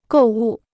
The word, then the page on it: 购物: gòu wù